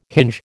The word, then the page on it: hinge: American English